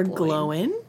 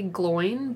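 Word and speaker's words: Glóin: Or Glowin. depending on how you want to pronounce depending. It. Depending. I always say gloin, but... – Yeah, I always say gloin, too. But I feel like I've heard it both ways.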